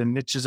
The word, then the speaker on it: niche: In the States, they say the niches are in the riches. It doesn't work so well for us Canadians because we pronounce it correctly. As niche.